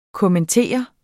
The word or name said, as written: kommentere